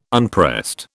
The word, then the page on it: unpressed: British English